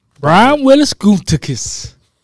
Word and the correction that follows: Gutekunst: How you say his name? – Did you say Willis? – That's his name. – Is that his middle name? – Yeah. Big Frenchie just don't make up names. – Wow. It's Gutekunst.